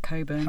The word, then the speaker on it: Cockburn: Cockburn in the Independent on the 10th of January. – I think that name is usually pronounced Coburn.